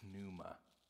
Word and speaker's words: pneuma: Actually, you'd pronounce the P, the pneuma.